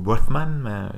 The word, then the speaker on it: Rothman: Ou Rothman, je pense que ça se prononce plus comme ça, mais euh, je l'ai prononcé à la française.